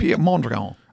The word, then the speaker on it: Piet Mondrian: How do you say his name? – Piet Mondrian.